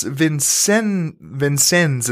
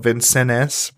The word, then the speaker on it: Vincennes: The U.S. Vincennes is the way they say it, right? It kind of looks like it's spelled Vincennes, but the U.S.